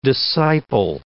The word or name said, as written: disciple